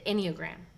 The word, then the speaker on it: Enneagram: And Melanie, um, you have a, a great awareness, and that is, I want to make sure I pronounce it correctly, the Enneagram. Perfect. Okay.